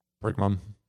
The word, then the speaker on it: Pergamum: Pergamum.